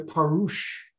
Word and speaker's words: Parush: Shaul shouted, Brothers, I myself are a Parush, that's spelled P-A-R-U-S-H, and a son of the Perishim, son of a Pharisee.